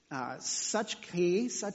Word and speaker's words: psuche: It's psuche. It's spelled P-S-U-C-H-E.